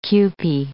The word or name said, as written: Kewpie